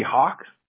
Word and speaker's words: Hawk: It's Sahak, S-A-H-A-K. But a lot of people just call me Hawk, H-A-W-K. I mean, whatever's better for you. Maybe a little easier to pronounce for sure.